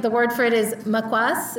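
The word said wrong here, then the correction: Maswak (مسواک): Is that right? Am I saying it right? I'm looking at the audience, yeah? Maswak. – Yeah, maswak. In Persian we say maswak.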